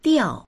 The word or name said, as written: diao4